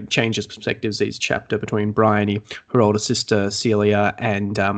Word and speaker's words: Bryony: Bryony? – Yeah, that's how I said it in my head. Bryony.